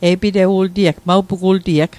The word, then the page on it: e'pitewit: Mi'gmaq